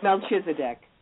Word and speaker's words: Melchizedek: Melchizedek.